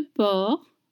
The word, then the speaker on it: porc: And you don't pronounce the C at the end of porc.